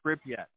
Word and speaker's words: Pripyat: Pripyat? – I believe it's Pripyat. Oh, Pripyat. – Pripyat. – Pripyat. Okay.